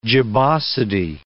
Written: gibbosity

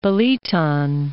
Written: Billiton

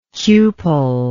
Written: cupellation

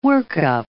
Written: workup

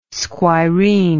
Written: squireen

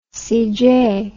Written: cj

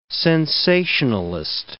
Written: sensationalist

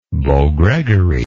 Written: beau gregory

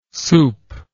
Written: supe

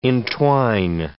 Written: entwine

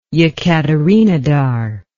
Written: Yekaterinodar

